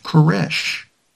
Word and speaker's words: Koresh: Vernon Wayne Howell is told that since you are Cyrus, you must take his name, and in Hebrew, Cyrus is pronounced Koresh.